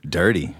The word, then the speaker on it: Dyrti: D-Y-R-T-I? – Dirty. – The Dirty. Is that how you say it? Is it pronounced dirty or is it dirty? – I'm not saying deer tay or deer tea. I'm saying dirty.